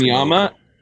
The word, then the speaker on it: Anyma: It's pronounced Anyama, A-N-Y-M-A.